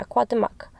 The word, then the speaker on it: Cuauhtémoc: Yeah, Cuauhtémoc.